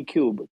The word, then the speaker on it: Kub: I mean, most people call me Cube, K-U-B. So, yeah, you can use the same Cube. – Okay. Cube. – CypherD wallet. – Cube. – K-U-B.